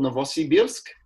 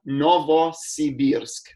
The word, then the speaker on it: Novosibirsk: Yeah, we're in a city called Novosibirsk, which means New Siberia. Novosibirsk. That's how you sort of remember how to pronounce it.